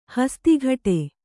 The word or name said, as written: ಹಸ್ತಕಟ್ಟು